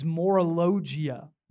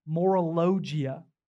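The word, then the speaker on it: morologia (μωρολογία): The Greek word for this, again, I'm going to try to pronounce this, is morologia. Morologia.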